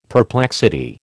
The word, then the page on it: perplexity: American English